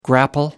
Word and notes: grapple: American English

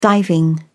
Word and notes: diving: British English

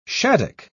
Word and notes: shaddock: British English

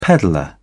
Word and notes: peddler: British English

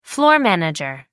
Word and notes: floor manager: American English

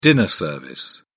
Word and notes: dinner service: British English

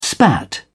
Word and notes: spat: British English

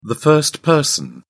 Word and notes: first person: British English

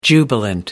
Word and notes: jubilant: American English

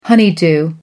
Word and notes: honeydew: American English

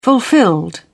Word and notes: fulfilled: British English

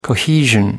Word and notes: cohesion: American English

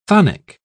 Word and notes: chthonic: British English

